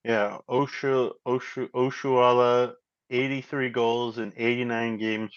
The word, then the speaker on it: Oshoala: Because I do think the threat of Oshala, I totally butchered that. Oshoala from Nigeria.